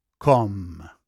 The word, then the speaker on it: Cosme: C-O-S-M-E. Je le prononce Cosme, mais en réalité, ça se prononce comme. Le Château Comme. C-O-S-M-E.